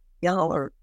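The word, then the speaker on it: yoğurt: And he allowed a French company to start producing what they called Yahurte, in commercial quantities. That spelling, that is a phonetic spelling of the Turkish word as pronounced in Turkish. There is no yogurt with a hard G in Turkish.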